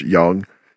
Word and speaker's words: Yung: It's consisting of Young Miami, spelled Y-U-N-G, but pronounced Young, and JT.